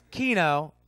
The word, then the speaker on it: Keano: Up next, we got, I want to make sure after years and years of pronouncing this the wrong way, that I say it the right way. It's Keanu. But it's spelled K-E-A-N-O.